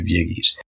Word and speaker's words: Gyges: That's G-Y-G-E-S, and it's pronounced Gyges, who was the richest man in the world.